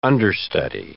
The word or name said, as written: understudy